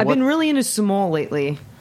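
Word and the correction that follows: Sumoll: The Spanish grape, it's S-U-M-O-L-L. Sumol.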